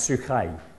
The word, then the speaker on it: psyche: That big X is a CH, and that thing on the end there that looks like an N with a long tail is a long E. So that's actually, that would be pronounced psyche.